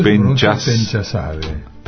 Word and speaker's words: Penchaszadeh: Mira, el apellido Penchasade. – Penchasade. Porque a veces viste que la H final. – No. – Uno, por ejemplo, dice Ahmadinejad. – Sí. No, pero no se pronuncia.